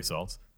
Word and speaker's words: Saltz: Yeah, last name rhymes with Maltz. Mm-hmm. Actually, it's Jerry Saltz.